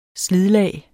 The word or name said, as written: slidlag